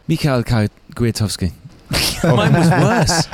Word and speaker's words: Michał Kwiatkowski: Right, you're going to have to tell me how you pronounce this properly in Polish, but Michał Kwiatkowski? – Close, close, very close. Michał Kwiatkowski.